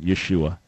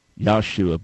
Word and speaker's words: Yeshua: And just to set the record straight, the original way of pronouncing the name of Jesus would have been Yeshua, not Yahshua, but Yeshua.